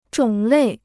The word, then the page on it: 种类: zhǒng lèi